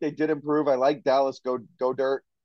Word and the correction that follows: Goedert: Goddard. – Goddard. – Another running gag. He doesn't know how to pronounce his name.